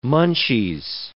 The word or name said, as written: munchies